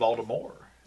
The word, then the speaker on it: Voldemort: It's Voldemort. Yeah. – The T is silent.